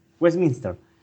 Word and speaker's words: Westminster: No te es de ahí, pero derecha, mejor pronunciado. – Claro, Westminster.